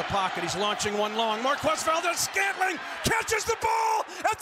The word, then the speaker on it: Marquez: And we're, of course, talking about Marquez Valdez, who gets his full name pronounced, by the way, when he's making catches. – Yeah, not MVS, Marquez.